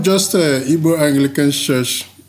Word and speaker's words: Igbo: As you think about, which I just learned, you don't pronounce the G. It's not Igbo. So I apologize for mispronouncing it, but it's Igbo.